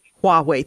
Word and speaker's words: Huawei: You point out that last year, uh, China's state sponsored, um, is it Huawei? How do you pronounce that? Technologies? Uh, it's Huawei. Huawei. Thank you.